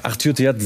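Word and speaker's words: Arthur Theate: Ja, Arthur Theat, sehr gut ausgesprochen. Spricht man Französisch, ja.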